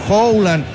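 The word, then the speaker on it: Haaland: Marcelo en Suecia, él quería, claro, dice esto Noruega, tienen que decir Howland en la pronunciación, pero bien, Marcelito, está bien.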